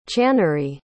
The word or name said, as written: channary